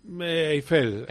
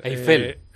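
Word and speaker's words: Eifel: ¿Es el gran premio de Eiffel o Eiffel? ¿Cómo se dice? – Eiffel. – Eiffel. – Eiffel, sí, pero no confundí con la torre.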